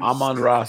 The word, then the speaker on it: Amon-Ra: Amaran. – Amon Ra Saint.